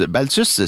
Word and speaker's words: Balthvs: Baltus, si jamais vous voulez chercher ce groupe-là pour en savoir plus, ben sachez que ça s'écrit B-A-L-T-H-V-S. Alors, on utilise le V un peu comme un U ici, vous comprenez.